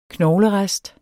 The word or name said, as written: knoglerest